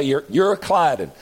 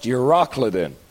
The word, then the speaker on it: Euroclydon: Some people say you're, you're Clyden, uh, but my Bible's got it pronounced Euroclydon.